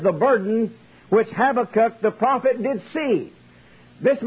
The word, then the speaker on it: Habakkuk: Habakkuk, some say Habakkuk, but it don't matter to me how you pronounce it.